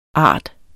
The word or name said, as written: ard